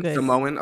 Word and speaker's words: Samoan: Did I say that right? Okay. – Samoan. – Samoan. Okay. Samoan.